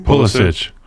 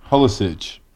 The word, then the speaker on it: Pulisic: Pulisic. – Pulisic.